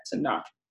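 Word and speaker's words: Tanakh: Okay, so in in Hebrews they don't have vowels, so you will see it written out as T and K like that Tanah, and it's pronounced Tanah, and the K is silent.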